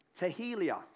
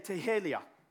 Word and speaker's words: tehillah: The other Hebrew word for praise, it's the word tehillah. Say tehillah.